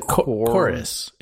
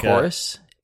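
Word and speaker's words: Chorvs: It's like Chorus. It's like Chorus. Chorus. Yeah. – It's like that band Churches. – Yeah. Replace the U with a V, please. Chorus.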